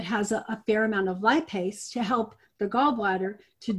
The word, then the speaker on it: lipase: Transformation Enzymes has one that's just lipase, and it's spelled L-I-P-A-S-E. And so you take that L-I-P-A-S-E. – A-S-E. A-S-E, Sam, as in Sam. L-I-P, like lip. Lip. – Okay. – A-S-E. A-S-E. – A-S-E. Lipase.